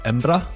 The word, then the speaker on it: Embra: Yeah, so most Scottish people say Edinburgh, but if you're really, truly a local, you say something more like Embra. – Oh, interesting. – I think you would spell it E-M-B-R-A, Embra.